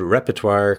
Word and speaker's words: repertoire: So this word repertoire comes from French and is pronounced kind of in a French way, repertoire.